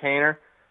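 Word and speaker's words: Haener: Jake, is it Hayner? – Yep, Jake Hayner.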